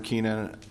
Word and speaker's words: Keena: Robert Keenan.